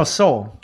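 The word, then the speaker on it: Soule: It is Sewell. – Okay. – No, Soul. Charles Soul. I'm sorry. Like okay. The sole of your shoe. – Soul. – Charles okay. – Soul.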